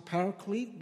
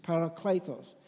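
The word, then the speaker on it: parakletos (παράκλητος): Some people say pronounce it paraclete, but actually it's in the Greek word, uh, there's two E in, in Greek. It's E or A. This is parakletos.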